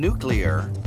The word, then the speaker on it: nuclear: And even though the words are properly pronounced harass, Uranus, and nuclear, we're still putting the commute in commuter entertainment.